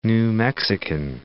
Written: New Mexican

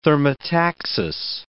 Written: thermotaxis

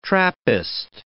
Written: Trappist

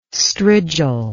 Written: strigil

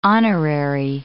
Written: honorary

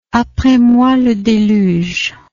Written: apres moi le deluge

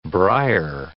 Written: Breyer

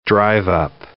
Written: drive-up

